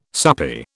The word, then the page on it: soupy: British English